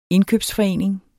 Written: indkøbsforening

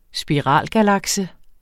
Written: spiralgalakse

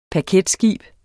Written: paketskib

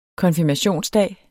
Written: konfirmationsdag

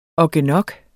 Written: ogginok

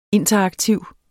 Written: interaktiv

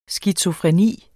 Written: SKIZOFRENI

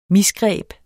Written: MISGREBENE

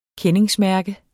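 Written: KENDINGSMÆRKER

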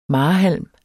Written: marehalm

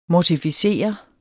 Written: mortificere

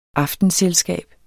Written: aftenselskab

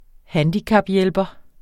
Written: handicaphjælper

